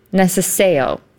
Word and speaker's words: necesejo: So the C is pronounced like a TS sound. – Okay. – Neseteo. – Neseteo, okay. Mm-hmm. – And the J is like a Y sound.